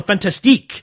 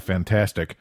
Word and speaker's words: Fantastique: But I have a feeling that those involved in Cinema Fantastique, and I didn't know any of them, You have to say, Cinema Fantastique. Some of the ex-writers insist on you pronounce the Fantastique. – I think that those involved with Cinema Fantastic.